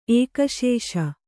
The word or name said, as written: ಏಕಶೇಷ